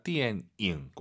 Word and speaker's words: 电影: 네, 니음 발음 들리면 안 돼요. 이응입니다. 电影 꼭 기억하시고요.